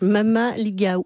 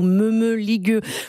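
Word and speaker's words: mămăligă: Voilà donc Mamaliga ou Me-me-ligueux pour le prononcer à la romaine de Zoé Barthélémy.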